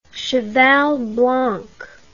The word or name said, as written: Cheval Blanc